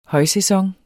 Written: højsæson

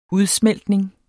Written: udsmeltning

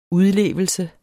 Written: udlevelse

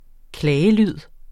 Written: klagelyd

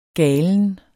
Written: galen

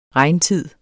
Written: regntid

